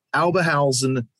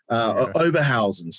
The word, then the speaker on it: Oberhausen: Oberhausen, Germany. Oberhausen, sorry.